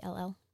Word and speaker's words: Kuykendall: Her last name was Willingham, but she is now, I'm going to butcher it, Kuykendall. It's Kuykendall.